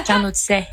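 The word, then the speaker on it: carnotzet: Alors, elle va t'expliquer ce que c'est, sauf qu'elle aussi, elle a un peu de mal à le prononcer. – J'ai fait une soirée dans un Carnotzé. C'est Carnotzé. J'ai trouvé, écoutez, le concept totalement ahurissant.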